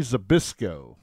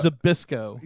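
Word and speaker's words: Zbyszko: That's Larry Zbysko. – Okay. You know, Zbysko.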